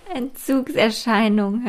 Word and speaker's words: Entzugserscheinung: Entzugserscheinigungen. Nein, falsch. Entzugserscheinung heißt es. Er Entzugserscheinungen. Habe ich es jetzt richtig gesagt? Entzugserscheinigungen. Äh. Nicht Erscheinigung, Erscheinung. Entzugserscheinung.